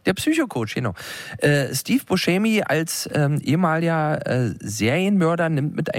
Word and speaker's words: Buscemi: Schrecklicher Titel, ein Film mit Steve Buscemi. Ach, ehrlich, so wird er ausgesprochen. Wir waren uns gerade unsicher. Aber viele sagen ja Buscemi. Und das ist natürlich völlig falsch.